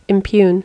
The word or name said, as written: impugn